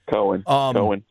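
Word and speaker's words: Coen: I don't even know how to say the dude's name. Cohen? – Cohen.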